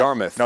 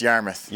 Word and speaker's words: Yarmouth: Yarmouth. – No, just Yarmouth.